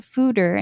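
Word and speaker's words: foeder: It's F-O-E-D-E-R, but you pronounce it fooder, and it actually just means large barrel from the translations that I've looked up anyways.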